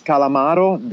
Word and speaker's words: Calamaro: My nickname is Calamaro. That actually, if you want, I can also spell it. It's C-A-L-A-M-A-R-O.